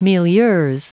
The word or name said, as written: milieux